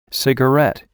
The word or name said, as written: cigarette